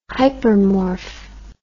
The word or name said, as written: hypermorph